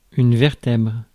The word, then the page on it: vertèbre: [vɛʁ.tɛbʁ]; France